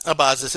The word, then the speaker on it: Abazis: I think I've been saying it wrong for like 3 years, but Abazis, it's okay.